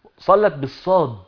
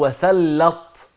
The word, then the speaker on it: صلت: صلت بالصاد مش بالسين، في بعض الناس هنا برضو بينطقوها غلط، ينطقوا الدعوة دي غلط، يقول وسلط من التسليط، ان ربنا يسلط عليكم الملائكة. لا، هي مش بالسين وانما بالصاد، وصلت عليكم الملائكة، صاد لام تاء، صلت من الصلاة، والصلاة هي الدعاء، يبقى ربنا يوفقكم ان الملائكه ترضى عنكم وتدعيلكم بالرحمه والقبول والرضا والرضوان.